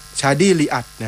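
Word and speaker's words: Chadi Riad: ชาดี้อ่านว่าลีดลีอักใช่ไหมต้องอ่านว่าอะไรวะชาดี้ลีอัดน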